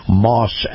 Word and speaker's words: Maus: My conversation with Chris Markowski, as always, is brought to you by Moss Acura. Now, Moss is spelled M-A-U-S, and if you love the feel of a high-end SUV, you're going to love Moss Acura.